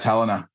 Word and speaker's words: Helena: How would you pronounce Helena Montana? – Well, now you have me second guessing how I always say it, which is Helena. But is it Helena? – Yeah, it's Helena.